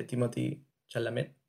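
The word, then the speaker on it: Timothée Chalamet: Y me gusta la representación que tiene el personaje de Timothy Chalamet. Espero haberlo pronunciado bien, no sé. Sí, lo hiciste bien.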